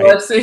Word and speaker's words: Biloxi: And I also love that you're in Biloxi. Did I just say that right? – Biloxi, yeah.